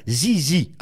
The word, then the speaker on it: ZZ Top: Et il faut prononcer ZZ, attention.